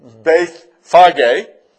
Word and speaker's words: Bethphage: And it came to pass that when he came near to, and it is pronounced Beth, how have you always pronounced the next word? Bethphage, or Bethpage. But it's Bethphage, and it's Bethania and Bethany,